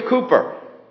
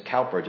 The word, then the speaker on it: Cowper: William Cooper, who unfortunately is condemned to having his name pronounced as Cowper just because it had a W in it.